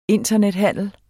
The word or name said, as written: internethandel